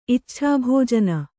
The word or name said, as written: ಇಚ್ಛಾಭೋಜನ